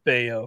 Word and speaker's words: Bello: And I saw him in the minors earlier this year and thought he was looked pretty impressive, Brian Bayo. Of course, B-E-L-L-O.